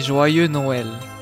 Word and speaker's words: Joyeux Noël: So you say Joyeux Noël. – Joyeux Noël.